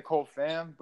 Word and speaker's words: Cole Pham: I like to say Cole Pham, but both are right.